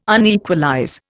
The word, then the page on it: unequalize: Indian